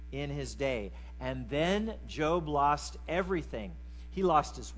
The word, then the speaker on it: Job: It's spelled J-O-B, so it's not a guy who was employed, like Job. His name is Job, right?